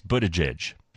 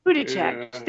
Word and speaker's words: Buttigieg: His name is Mayor Pete Buttigieg. – Buttigieg.